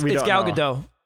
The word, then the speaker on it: Gadot: I think, I don't know how to pronounce Gal Gadot's last name. It might be Gadot or Gadot. – No, it's Gal Gadot. – Is it though? Because I've heard other people say it the other way too. – Well, I've heard Gal Gadot a lot, so...